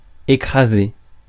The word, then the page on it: crash: /kɹæʃ/